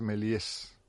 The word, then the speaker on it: Méliès: Y en Barcelona debe ser que hay un Cine Melié, cosa que yo ignoraba. – No, pero quedamos en que es Melies. Porque se pronuncia así en francés.